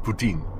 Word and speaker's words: Putin: And it was from then that we see the uh, rise of Putin, okay, which actually pronounced Putin, uh, very much like those little snack bowls in, um, in Canada.